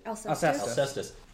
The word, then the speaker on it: Alcestis: That didn't make sense that she was, I can't even pronounce it. How do you say it? – Alcestis.